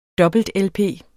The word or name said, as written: dobbelt-lp